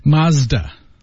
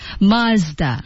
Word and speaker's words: Mazda: Mazda. – Mazda. – Yeah, two syllables. – 拼写。 – M-A-Z-D-A, Mazda.